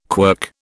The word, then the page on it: quirk: British English